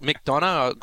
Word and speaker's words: McDonough: So didn't see the GH is silent. Come on. – Yeah, so so she got McDonough,